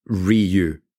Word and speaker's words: Ryu: Because I think the actual pronunciation is Ryu.